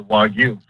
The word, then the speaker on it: Wagyu: Of just doing research and trying to figure out what was the best beef, we came down to Wagyu. – Sounds right to me. – That's not what you say. I want you to admit right now that Wagyu is not right.